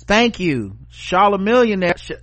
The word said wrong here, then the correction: Chamillionaire: Chamillionaire, Chamillionaire, Damn, it's been so long since he was famous, I forgot how to pronounce Chameleonaire.